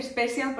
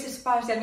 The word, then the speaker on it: Spatial: Spatial, especial No quiero decir Special porque es Spatial.